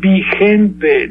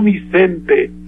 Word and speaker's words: vigente: Así es que lo dicho por Vargas Llosa es vigente, no Vicente, porque no puedo pronunciar por el catarro. Vigente. Con G, ¿eh? – Sí, sí.